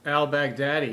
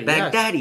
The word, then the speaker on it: al-Baghdadi: You know, he didn't tell Baghdadi. Am I saying his name right? – Al-Baghdadi. – Baghdadi. – Yes.